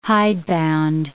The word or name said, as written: hidebound